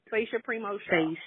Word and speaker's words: Sache Primeaux-Shaw: Sasha Primo Shaw.